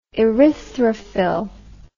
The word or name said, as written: erythrophyll